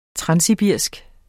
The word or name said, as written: transsibirisk